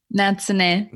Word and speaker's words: Natsune: Natsune.